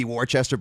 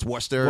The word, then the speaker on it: Worcester: It's like there's one that, like, it should be Worcester, but it's Worcester.